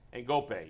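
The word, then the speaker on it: Ngoepe: Ngope, yeah. – Ngope. – Okay, so it must not be. It must be. There's never been a baseball player from this guy's country. Where's Gift Ngope from? – South Africa.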